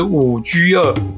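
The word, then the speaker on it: 五车二: 这个啊，就是五 G 二，数字的五，车子的车，不过它要念成古音，就是 G 二，也就是数字的二。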